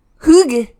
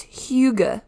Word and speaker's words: Hygge: It's Hygge, not Hygge.